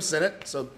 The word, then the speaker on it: Sinnott's: Am I saying that right? Sinnott's? – Yep, Sinnott's Bar.